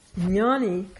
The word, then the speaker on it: jna: Jnani comes from the root jna. J-N with a little wiggle on the top. Long A, jna.